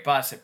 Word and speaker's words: Boeser: I can't even say his name right now, but you know, is it Besser? Okay, great. Besser.